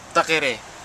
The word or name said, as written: takere